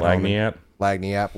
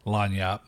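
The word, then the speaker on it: lagniappe: Lagniap. – Well, see, you say it one way. How do you say it? – Lagniap. – Lagniap. So whatever it's called. – It's French. The G would be silent.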